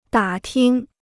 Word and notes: 打听: dǎ ting

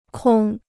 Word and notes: 空: kòng

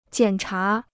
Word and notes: 检查: jiǎn chá

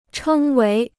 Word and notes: 称为: chēng wéi

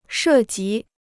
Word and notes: 涉及: shè jí

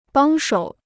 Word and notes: 帮手: bāng shǒu

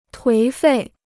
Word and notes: 颓废: tuí fèi

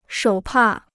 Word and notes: 手帕: shǒu pà

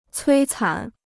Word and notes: 摧残: cuī cán

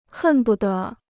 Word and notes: 恨不得: hèn bu de

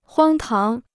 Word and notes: 荒唐: huāng táng

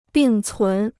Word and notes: 并存: bìng cún

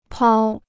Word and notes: 抛: pāo

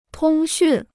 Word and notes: 通讯: tōng xùn

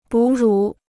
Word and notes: 哺乳: bǔ rǔ

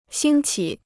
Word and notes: 兴起: xīng qǐ

